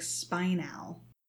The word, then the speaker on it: spinel: The audiobook says spinel, like spinal, spinal, spinal, spinel, spinal, spinel. You tell me which one sounds more like a mineral.